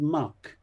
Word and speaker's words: Mack: The local beer they were drinking was spelt Mac, M-A-C-K, but pronounced by the locals as Muck.